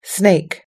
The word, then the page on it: snake: American English